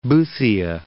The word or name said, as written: Boothia, Gulf of